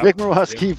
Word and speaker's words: Murawski: Nick Murawski from Good Guys Talk Back and Locked On Sox, the daily podcast.